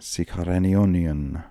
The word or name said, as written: sikharenionian